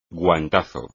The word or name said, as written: guantazo